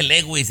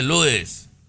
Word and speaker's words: Lewis: Y ya le dije que no se dice Lewis, se dice Lewis.